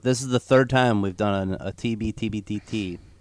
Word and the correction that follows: TBTBBT: I just butchered that. TBTBBT.